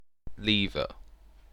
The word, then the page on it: lever: British English